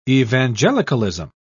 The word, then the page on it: evangelicalism: British English